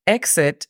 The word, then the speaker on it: exit: Exit significa salida. La pronunciación fonética es E-X-E-T, exit.